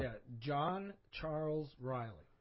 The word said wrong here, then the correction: Ryle: or Ryle, excuse me. If you want, I pronounce the E. No.